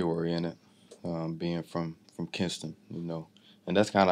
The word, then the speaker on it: Kinston: Kinston. Yeah. I'm saying it right? – Yeah. Kinston, North Carolina. They're going to kill you if you say it wrong.